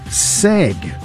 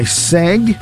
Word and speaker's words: segue: We know that acai was the first name, the first word that was mispronounced mostly, followed by seg, S-E-G-U-E, seg.